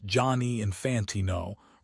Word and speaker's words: Gianni Infantino: FIFA President Gianni Infantino, pronounced Johnny Infantino, remains in power, despite the controversy.